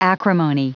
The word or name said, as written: acrimony